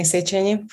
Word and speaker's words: Szecsenyi: Uh, my name is Blanka Petyusne Szecsenyi for the authentic pronunciation of my name.